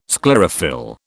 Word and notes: sclerophyll: British English

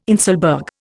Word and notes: inselberg: Indian

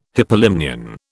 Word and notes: hypolimnion: British English

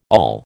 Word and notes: awl: American English

